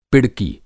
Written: पिड़की

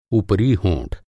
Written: होंठ

